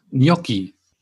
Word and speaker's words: Gnocchi: Das G ist stumm und die zwei C sind wieder wie ihr G. Habe ich das richtig gesagt? Ja. – Gnocchi. Und, genau. Und so das N spricht man so ein bisschen mehr irgendwie.